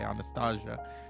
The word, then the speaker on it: Anastasia: She won again for Anastasia, but I say Anastasia because that's how you say it.